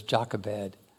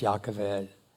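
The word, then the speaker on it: Yocheved: The mother of Moses was, we always pronounce it in English, Jochebed, but it's Yochevedt.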